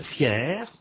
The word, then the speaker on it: fier: Notez que le R de fier au masculin est prononcé.